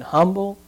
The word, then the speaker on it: humble: Somebody told me the other day, it said, you know, a Baptist, because they don't pronounce the H in humble, and they say Revelations.